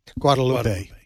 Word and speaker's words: Guadalupe: Guadalupe.